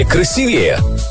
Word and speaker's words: красивее: Как сказать правильно? Красивее или красивее? Подумайте несколько секунд. Правильно сказать «красивее».